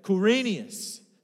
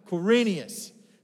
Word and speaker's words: Quirinius: Everybody say it together. Quirinius. Say it one more time. Quirinius. So you'll never get tripped up again, kids. It's Quirinius.